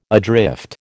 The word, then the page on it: adrift: American English